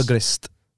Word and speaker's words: gris: Gris. – Oh, really? – Gris. – Yeah. – Gris. Well, yeah, I think it's spelled gris. G-R-I-S.